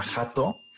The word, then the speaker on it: hot dog: De pronunciar la hot dog, en todo el norte, bueno, en la mayoría del norte de la República lo pronuncian como jato. ¿A jato? Jato, exactamente. Y así los ves en los carritos jato. J-A-T-O.